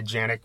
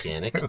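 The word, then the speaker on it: Janick: I always say Janik, but is it Yannick? Is that how you say it? – I'm not sure. I think it's Yannick.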